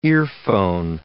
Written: earphone

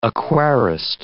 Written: aquarist

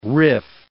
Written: riff

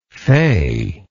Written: feyness

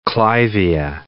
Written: clivia

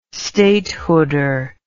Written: statehooder

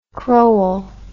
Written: crowell

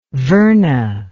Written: verna

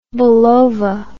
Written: Bulova

